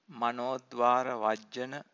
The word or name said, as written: manodvaravajjana